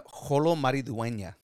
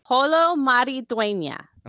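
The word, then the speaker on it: Xolo Maridueña: Say his name. Manduena. – No, Holo Mariduena. – Holo Mariduena.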